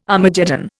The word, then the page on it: Armageddon: Indian